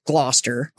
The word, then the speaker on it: Gloucester: So there is a place called Gloucester. – Gloucester, Mass. – Which is actually spelled Gloucester. – Didn't need to know that. – You just don't pronounce everything in the middle.